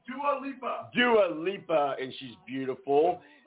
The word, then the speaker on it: Dua Lipa: I said it wrong. All right, guys, so that was, what is it again? Come say it. – Dua Lipa. Dua Lipa.